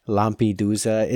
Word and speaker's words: Lampedusa: I even have a little thing in here about how to pronounce Lampedusa. – It, and I butchered it. Lampedusa. It's kind of an S and a Z, like, you know how Italian goes.